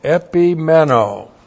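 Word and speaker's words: Epimeno (ἐπιμένω): E-P-I-M-E-N, long O. Epimeno.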